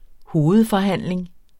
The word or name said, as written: hovedforhandling